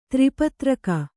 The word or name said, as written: ತ್ರಿಪತ್ರಕ